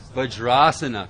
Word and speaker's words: Vajrasana: Vajrasana. Very good. See? You're learning. So that would become a long A. That would become a long A there. Vajrasana.